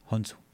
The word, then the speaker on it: Khonsu: Entonces, Thoth acudió al dios de la luna, Khonsu. Okay. Bueno, Khonsu. – Khonsu. Pues es el de literal Moon Knight. Vean en Marvel. – Ándale. ¿Cómo es? ¿Khonsu o Khonsu? – Khonsu.